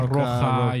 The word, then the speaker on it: Rocha: Met um, de la Roca, zeg ik dat goed? Ja, Zack de la Roca, Roca, Rocha, joh, allebei.